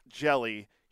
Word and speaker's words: Hjelle: As in jellyfish. – Yeah, it's spelled H-J-E-L-L-E, but it's pronounced Jelly.